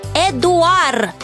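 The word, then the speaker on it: Édouard: О, теперь ясно, значит, во Франции это имя произносится как Эдуар.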